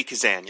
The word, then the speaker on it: Kazan: Kazan? Is that right? – Yeah, Lainey Kazan.